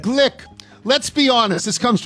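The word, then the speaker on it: Glück: So a lot of these things are about Louise, and I thought her last name was Gluck because of the umlaut. I thought it was either Gluck or Gluck. And you say it's pronounced... – Apparently it's Glick.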